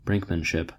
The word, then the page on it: brinkmanship: American English